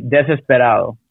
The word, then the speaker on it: desesperado: Yeah, in Spanish it would be Desesperado.